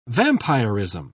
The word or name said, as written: vampirism